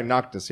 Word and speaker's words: Noctis: Noct- sorry, Noctis.